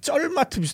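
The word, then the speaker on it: Zermatt: 어디서 봐도 이렇게 딱 보이고 이 밑에 있는 이제 마을 이름이 체르마트인데 체르마트. – 체르마트. – 네. 뭐 현지 발음은 약간 이제 쩔마트 비슷하게 발음. 체르마트. 이렇게 발음하더라고요.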